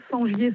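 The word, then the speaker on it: Sengier: Sur mon nom, Sangier Sandrine. Et il y a tout... – Sangier, ça s'écrit comment? – Alors, S-E-N.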